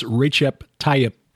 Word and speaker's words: Recep Tayyip: I'm reliably informed it's Recep Tayyip. A fellow who was an expat teaching English in Turkey for several years, Recep, the first name. – Recep Tayyip Erdogan.